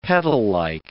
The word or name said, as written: petallike